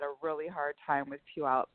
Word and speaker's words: Puyallup: And to Penny, I think it's Salm from Puyallup, Washington. And by the way, Charlie, you pronounce Puyallup much better than I did when I first moved here 22 years ago.